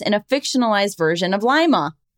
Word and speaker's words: Lima: Next up, we have Robin, correction, Lima, Ohio. Oh, shit. Oh, it's pronounced with a long I, as in lima bean, not as in Lima, Peru.